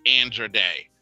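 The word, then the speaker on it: Andra Day: I'm saying Andrea it because Day. All of us were pronouncing it differently for like years. She never corrected anybody. Now it's Andrea Day.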